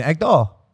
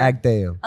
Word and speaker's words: Agdal: Am I saying that right? – No, but. – I never get this right. Help. – Never. – Agdal. – Unless you learn to speak Agdal. – Agdal.